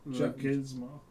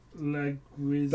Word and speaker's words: Leguizamo: I can't pronounce his actual name. What was it? John Leguizamo. – Leguizamo? – Leguizamo.